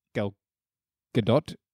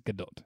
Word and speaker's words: Gal Gadot: Gal Gadot is the pronunciation. – Yes. Okay. – I looked it up. – Okay. – Because we had that discussion the last time I said her name, and it's Gadot.